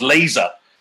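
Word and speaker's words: Leese: And in fact, the keeper's name was not pronounced Lars Lees. It was Lars Laser, thus rendering his lovely six-line rhyme completely redundant.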